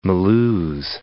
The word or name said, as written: Mulhouse